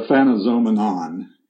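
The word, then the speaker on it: Aphanizomenon: Yes, I've heard it said different ways, but the Phanizomenon is probably the correct phonetic pronunciation.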